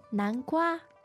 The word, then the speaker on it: 南瓜: 南瓜. 南瓜. – 南瓜. The tones there sound like first and... No, second, is it? – Yes. – And first.